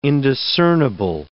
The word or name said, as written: indiscernible